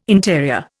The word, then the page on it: interior: Indian